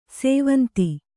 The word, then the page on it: ಸ್ವಾಂತ: svānta